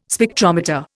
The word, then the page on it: Spectrometer: Indian